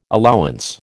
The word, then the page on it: allowance: American English